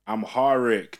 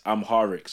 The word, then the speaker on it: Amharic: And that language is Ge'ez, which is like uh, the language that modern Hamric, I'm butchering that. – Hamric, is, is based off. This is Ethiopian language, essentially. Amharic. That's it, guys. Amharic.